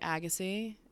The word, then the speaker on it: Agassi: He kind of looked like, am I saying this right? Agassi? Yes, he did, right? Yeah. He did look like Agassi.